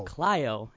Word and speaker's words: Clio: How do you pronounce what city we are in right now? – Clio. – So Clio, yeah.